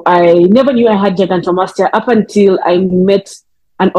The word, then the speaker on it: gigantomastia: gigastomia, did I pronounce it correctly, foundation? – Gigantomastia Foundation.